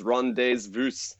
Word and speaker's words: rendezvous: And ooh, the French pronunciation is Rendezvous, I believe, if that's correct.